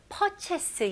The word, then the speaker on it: purchase: 여기서는 per, 여기에 r가 들어가 있죠. 그래서 r는 힘을 주셔야 됩니다. purchase 이런 식으로 하시면 안 되고 purchase. purchase. 마지막 그 chase 이거를 chase라고 발음하시는 것보다는 chase. chase. 약간 i하고 e 소리예요.